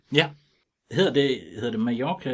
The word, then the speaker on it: Mallorca: så hedder det Mallorca. – Nej, det hedder Mallorca. På spansk to eller efter hinanden, det er udtalt som J.